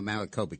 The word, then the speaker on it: Maricopa: That she was, that her election bid was stolen in Arizona, in Karamika County, is that? – No, Maricopa County, yeah.